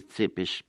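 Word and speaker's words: Țepeș: Se pronuncia realmente Tepes porque tanto la T como la S finales tienen pegada en la parte de abajo una especie de coma, y eso transforma la T en Tse y a la S en She.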